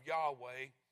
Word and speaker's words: Yahweh: The first temple of Yahweh, the spelling of that word in the Old Testament is capital Y, capital H, capital W, and capital H. We pronounce it Yahweh.